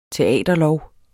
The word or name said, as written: teaterlov